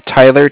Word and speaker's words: Tyler: My name is, like I said, Tyler, T-Y-L-E-R. I'm calling with Cardiff. It's spelled C-A-R-D-I-F-F, as in Frank Frank.